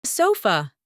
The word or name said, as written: sofa